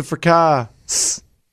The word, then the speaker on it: fracas: Is it a silent S on fracas? – It's a fracas. Yeah.